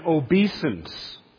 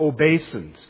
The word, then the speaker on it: obeisance: Obstinance and obeisance, or obeisance, can be pronounced both ways.